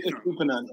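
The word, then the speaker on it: Estupiñán: Why is it Ian when it says Nan? – No, it's Estupian.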